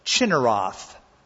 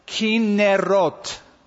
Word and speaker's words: Chinneroth: Then would you notice in verse 2 that word I drew attention to a moment ago, Chinneroth. That's really not how it's pronounced, even though it's spelled that way. It is pronounced Kinnerot.